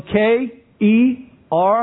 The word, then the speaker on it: kerygma: Kerygma is spelled K. E-R-Y-G-M-A, kerygma.